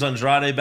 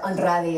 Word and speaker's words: Andrade: You should probably roll your R's for Andrade as well.